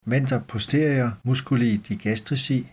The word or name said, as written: venter posterior m. digastrici